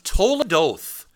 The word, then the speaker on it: toledoth: And then there are these things called toledoth. Rhymes with hold the door.